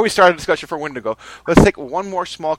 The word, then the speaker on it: Wendigo: So before we start our second episode discussion of, I believe, I can't pronounce this right, Katie, what is it? Wendigo? Wendigo? – Wendigo. – Wendigo. – That's how I see it, yeah.